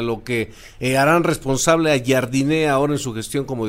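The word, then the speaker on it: Jardine: Sí, porque la verdad es que yo estuve investigando, porque los reporteros que lo entrevistaban en San Luis le decían Jardín, entonces pensamos que era así, después lo escuchamos pronunciarlo y él no lo pronunciaba así, lo pronunció Jardiné, y después resulta que ya Baños abre la conferencia diciendo, primero que otra cosa, les vamos a explicar y les vamos a decir cómo es. Tiene como acento en la I, Jardiné, para que se lo digan a todos sus compañeros y ya no haya dudas de la pronunciación.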